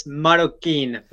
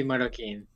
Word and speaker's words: Marroquín: Am I pronouncing your last name correctly? – It's Marroquín for the last name. Matthew Marroquín.